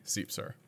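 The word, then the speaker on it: Siepser: You can find me on all the major social media networks at B Seepser. – How do you spell that? – Spell that B-S-I-E-P-S-E-R.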